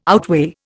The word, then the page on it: outweigh: Indian